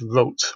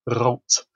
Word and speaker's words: Roth: However, a great many of the scholars and enthusiasts for his writing who I've spoken to in the last few years have pronounced his surname as he would have done, which is Rolt, or if you want to be really Germanic about it, with a kind of rolled R, Rolt.